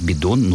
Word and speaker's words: бидон: А вот «бидон» произносить через «т» – это грубейшая ошибка, которая характеризует речь носителей просторечия. Извините за неизбежную тавтологию. То есть «бидон» нужно произносить как «бидон», и никаких других вариантов здесь нет.